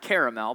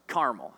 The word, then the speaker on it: Carmel: Not caramel, but Carmel. And I don't know how you pronounce it,